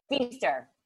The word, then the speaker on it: Pfeister: Sorry, Pfeister. – Sure. All right, I've been saying it wrong. I called you the Pfeister. Feaster. – Yeah, it's like feast on this.